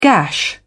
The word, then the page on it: gash: British English